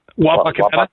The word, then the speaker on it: Wapakoneta: Wapakoneta? – yes, thank you.